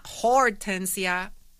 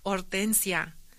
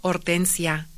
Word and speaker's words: Hortensia: Hortensia, that's my name, not Hortensia, it's Hortensia. I am tired of constantly telling and correcting folks that H is silent in Spanish. And you pronounce the O, Hortensia.